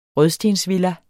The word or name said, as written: rødstensvilla